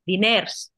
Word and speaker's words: diners: Dinero, en catalán se dice dinés, diners, pronuncian la R, nosotros no y nosotros decimos doblés, que se escribe doblers, ¿no?